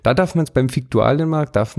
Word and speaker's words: Viktualienmarkt: Schreibt man eigentlich mit V, aber man sagt unbedingt Fiktualienmarkt. So heißt's. Und dann bin ich auf den äh, Fiktualienmarkt gegangen und habe geguckt,